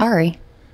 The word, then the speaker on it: Aerie: How would you say that? A-E-R-I-E. A-E-R-I-E? Ari? – Ari. – All right. What's it? – Is it the Aerie? – Is it Aerie, the clothing brand? – Yes.